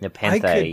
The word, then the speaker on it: Nepenthe: And today we're talking about Star Trek Picard, episode 7, Nepenthe. I think that's how you pronounce it. Is that right? – Nepenthe, Nepenthe.